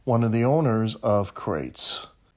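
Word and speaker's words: Qrates: It's pronounced Crates, but it's Q-R-A-T-E-S.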